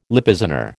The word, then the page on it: Lippizaner: American English